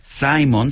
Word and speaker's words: Simon: Dato curioso, Simon se pronuncia en inglés, ya que es la NOAA de Estados Unidos la que asigna nombres.